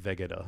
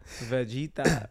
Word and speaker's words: Vegeta: So in my head, I've been calling him Vegeta. – Yeah, that's not it at all. Vegeta.